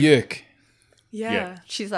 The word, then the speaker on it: Björk: Yeah, I think she said in an interview once it's Björk, like jerk.